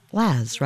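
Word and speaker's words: La's: Most people seem to be calling them the Laz, because it's spelled L-A apostrophe S. But it's ostensibly the elision in Liverpool, in Liverpudlianese, or whatever term one would use for the word lads. So you would say it Laz, right?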